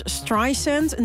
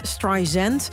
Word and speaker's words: Streisand: En van achteren heet ze dus Streisand, niet Streisand. Die uitspraak stoorde haar zo ooit dat ze Tim Cook van Apple belde om te klagen over Siri. – It's dry sand, like sand on the beach.